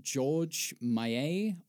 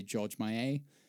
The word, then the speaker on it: Méliès: And while there were films that came before his, I think Georges Méliès or Georges Méliès, depending on how you're pronouncing it, I'd say Georges Méliès. – Yeah. – S's are always silent in French.